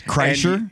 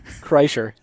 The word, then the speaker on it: Kreischer: Wallace gave you the pronunciation. – Yes, Kreischer. – Three times. – Bert Kreischer. Oh, jeez. Chapman. Kreischer. There it is.